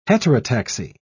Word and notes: heterotaxy: British English

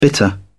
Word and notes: bitter: British English

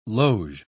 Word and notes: loge: British English

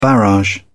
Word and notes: barrage: British English

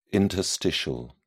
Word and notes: interstitial: British English

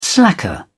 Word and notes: slacker: British English

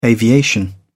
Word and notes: aviation: British English

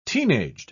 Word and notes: teenaged: British English

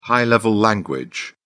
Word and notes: high-level language: British English

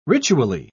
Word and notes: ritually: British English

